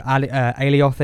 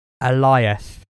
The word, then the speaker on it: Alioth: So, well, episode 5, you know, you've got Kid Loki, like the other Loki variants explaining what's going on, explaining who Alioth is. You know, the guard dog, the swallower of worlds. You've then got the Alioth. – Have I said that wrong? I've said that wrong. Alioth.